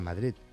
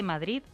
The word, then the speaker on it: Madrid: Pero te ha dejado impronta porque pronuncias la D de Madrid. Eso los madrileños no sabemos hacerlo.